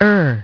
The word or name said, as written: Ur